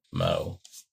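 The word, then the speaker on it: Meaux: I'm gonna pronounce it Mo. It's M-E-A-U-X, which I think is Mo. – Yeah, Mo.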